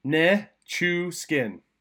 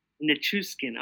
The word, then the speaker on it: Nichushkin: I can't say his name. – Valeri Nichushkin. – Nichushkin. I still don't think I said it right. – Nichushkin. – Nichushkin. Okay, there we go. – You got it.